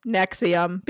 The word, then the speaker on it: NXIVM: So it is spelled N-X-I-V-M. But it is pronounced Nexium, because why the fuck not? – That's kind of cool.